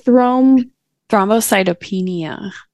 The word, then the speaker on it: Thrombocytopenia: Thrombocytopenia. – Thrombocytopenia is a diagnosis of exclusion. The condition is asymptomatic, usually occurs in the second half of pregnancy in the absence of history of thrombocytopenia.